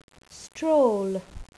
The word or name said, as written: stroll